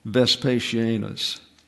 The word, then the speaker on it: Vespasianus: Somehow the I dropped down, I don't know why, but the I belongs up there, and it's S sound at the end. Vespasianus.